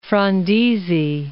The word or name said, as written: Frondizi